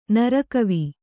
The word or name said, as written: ನರಕವಿ